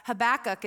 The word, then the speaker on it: Habakkuk: Habakkuk is, and that's how you pronounce it.